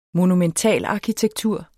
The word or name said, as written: monumental